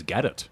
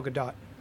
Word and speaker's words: Gadot: I believe it is Gadot. – Gadot. – Gadot. – Gadot. But not Gadot. – Right. – Because it's not French.